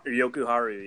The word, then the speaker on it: Jokiharju: And then that, I can't pronounce his last name yet. – It's Jokiharju. – Jokiharju, yeah.